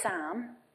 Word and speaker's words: Psalm: They've named the child, now not Sam as in Samuel, S-A-M, but Sam as in, I'm going to have to check how to spell it here, P-S-A-L-M. Sam. Sam West.